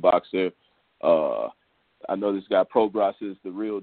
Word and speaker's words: Prograis: Progress. Is that how you say his name? He's fighting. – Yeah, Regis Progress.